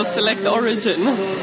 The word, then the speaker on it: Select Origin: Det rätta uttalet blir då select origin.